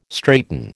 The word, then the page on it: straighten: American English